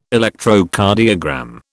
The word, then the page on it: Electrocardiogram: British English